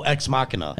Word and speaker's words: Ex Machina: Ex Machina, Ex Machina. – Oh, Ex Machina.